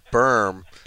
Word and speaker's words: Birm: I mean, that's just happens to rhyme with terminology, and my name is Berm.